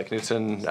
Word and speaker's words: Knutson: Knutson. Knutson. I never get the pronunciation.